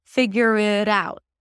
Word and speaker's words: figure it out: And then the T becomes a flap T because it's between two vowels. So I'm not saying figure it out. Okay, I don't pronounce it as a real T. I pronounce it as a flap T, which sounds like a D or a very light D.